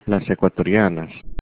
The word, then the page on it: ecuatorianas: Spanish